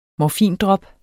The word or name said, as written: morfindrop